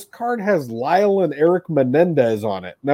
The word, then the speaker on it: Menendez: The Menendez brothers.